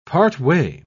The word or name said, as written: partway